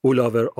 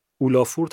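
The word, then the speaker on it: Ólafur: اولاور آرنالدز کار کردن اولافور تلفظ غلطیه شاید تو انگلیسی بهش بگن اولافور نمیدونم واقعا اما ایسلندیش میشه اولاور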